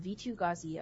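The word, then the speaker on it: Vitu-Ghazi: Think of the locket as a CG bear, spreading life from V2 Gauzy across all of Ravnica.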